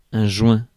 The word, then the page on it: seal: /siːl/; [siːl]